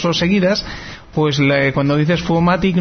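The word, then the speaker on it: Foomatic: Sí, pero no, sí, es INTE, F-O-M. – F-O-O. – O-O-M-A-T, vale. Y latina. – Vale, C. – Pero F-O-O-F-O no se dice FO, se dice FU. – Se dice FU, o sea. Entonces, que Fumatic.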